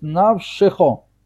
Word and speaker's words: навшихо: Когда у нас встречаются две буквы, под которыми обоим стоит шва, правила говорят, что нужно произносить второе. Второе нужно его произносить, то есть навшихо. Навшихо.